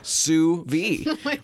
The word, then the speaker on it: sous vide: I was going to say it's pronounced sous vide, which is what I've been saying. Now, it's spelled S-O-U... – V-D-E, yeah. – V-D-E. – Yeah. – Not V-I-D-E? – I don't believe so.